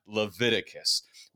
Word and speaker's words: Leviticus: Yes, I said that correctly, Leviticus.